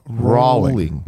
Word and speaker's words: Rowling: Rowling. – Rolling. – Rolling. – Rolling. Yeah. I'm saying it wrong already.